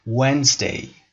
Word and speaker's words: Wednesday: تلفظ حرف اول یادتون نره. و هست. یعنی لب به صورت قنچه‌ای میشه و شما لبتون رو گاز نمیگیرید مثل حرف وی. و. و. Wednesday. و این رو هم دقت داشته باشید که حرف دی که در اینجا وجود داره یه حرف silent letter هست، یعنی ما تلفظش نمی کنیم، نمی خونیم Wednesday.